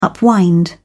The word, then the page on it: upwind: British English